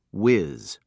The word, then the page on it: wiz: British English